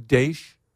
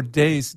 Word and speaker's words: Daesh: And the reason is that it sounds like the Arabic word for Daesh, D-A-E-S, instead of D-A-E-S-H. So it's not quite that the sh sound at the end, not quite as hard.